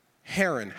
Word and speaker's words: Haran: What did you say? Haran. How do you spell that? H-A-R-A-N. Haran.